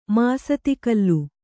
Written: ಮಾಸ್ತಿಕಲ್ಲು

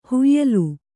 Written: ಹೊಯ್ಯಲು

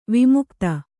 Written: ವಿಮುಗ್ಧ

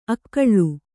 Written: ಅಕ್ಕುಲ

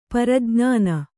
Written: ಪ್ರಜ್ಞಾನಿ

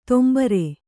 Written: ತೊಂಬರೆ

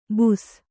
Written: ಬುಸ

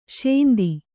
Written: ಶಾಂತಿ

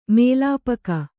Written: ಮೆಲ್ಪೊಗೆ

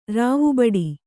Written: ರಾವುಬಡಿ

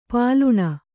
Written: ಪಾಲುಣ

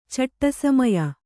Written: ಚಟ್ಟಸಮಯ

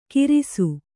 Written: ಕಿರಿಸು

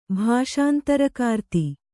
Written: ಭಾಷಾಂತರಕಾರ್ತಿ

